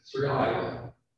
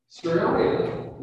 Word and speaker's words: Seraiah: So Ezra was the son of Seraiah. That's how you pronounce it. Seraiah. Seraiah was the last high priest in Jerusalem when Babylon conquered the first temple.